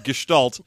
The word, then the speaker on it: gestalt: Thank you for the correct German pronunciation of gestalt. – You are welcome. – I was about to say gestalt and be all wrong about it.